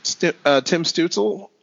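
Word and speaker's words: Stützel: Stutzel. Well, Tim Stutzel. – It sounds good to me. That's how I would have said it.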